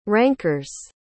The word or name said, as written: ranker's